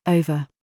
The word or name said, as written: GOAT